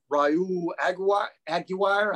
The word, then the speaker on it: Raul Aguirre: And two guys I wouldn't predict, but I want to find out more on right now is linebacker Raul Aguirre. I think is how you say his name. Yes, that's exactly how he's supposed to pronounce it.